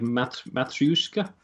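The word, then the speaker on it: matryoshka: Well, we call them babushkas, but they're really... get my pronunciation right. Something like matryushka? – Yeah, I'm not even going to make you say that again. That is correct. – Thank you very much. – Matryushka dolls is correct.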